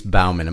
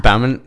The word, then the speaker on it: Baumann: Today in the studio, my living room, we have Elise Baumann. Am I saying that right ever? – Yeah. – Baumann? – Yeah. Elise Baumann. – It's the German, right? – Yeah.